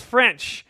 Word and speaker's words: Ffrench: But there's a gentleman named Maurice Ffrench, and yes, that's spelled French. Ffrench.